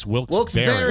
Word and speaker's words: Wilkes-Barre: I've said Wilkes-Barre, Pennsylvania for many, many moons, and it's Wilkes-Barre, B-E-R-R-E. – They make a great pie. – Yeah, this, no why. – The second half is spelled B-A-R-R-E, so a lot of people say Wilkes-Barre, but it's Wilkes-Barre.